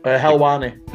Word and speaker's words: Helwani: Helwani.